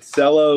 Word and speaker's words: Cello: But Cello, like, rhymes with Jello.